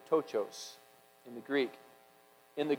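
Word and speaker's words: tochos (πτωχός): It is tochos, the P is silent, which describes absolute and abject poverty.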